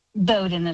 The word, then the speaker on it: Bowden: Dr. Bowden, I will tell you that, and am I saying the name right, Bowden or Bowden? – Bowden is right.